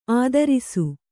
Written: ಆದರ್ಶ

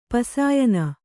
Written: ಪಸಾಯನ

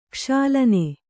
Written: ಕ್ಷಾಲನೆ

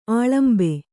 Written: ಅಳಂಬು

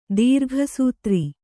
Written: ದೀರ್ಘಸೂತ್ರ

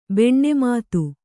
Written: ಭಾನುಮತಿ

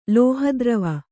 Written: ಲೋಹದ್ರವ

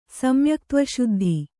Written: ಸಮ್ಯಕ್ತ್ವಶುದ್ಧಿ

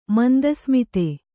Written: ಮಂದಸ್ಮಿತೆ

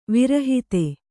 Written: ವಿರಹಿತ

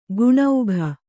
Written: ಗುಣೌಘ

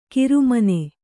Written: ಕಿರುಮನೆ

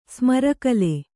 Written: ಸ್ಮರಕಲೆ

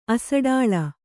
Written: ಅಸಡಾಳ

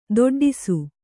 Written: ತಾಟಾಡಿಸು

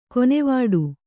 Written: ಕೊನೆವಾಡು

